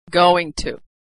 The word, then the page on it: going to: American English